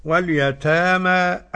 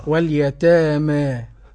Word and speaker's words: اليتامى: اليتامى ناخد فيها فتح وتقليل لورش. – نعم. – وناخد فيها إمالة لحمزة والكساء وخلف العاشر. – نعم. – والباقون بالفتح. – نسمع نطقها فضيلة الشيخ. – طيب. واليتامى أجل الفتح.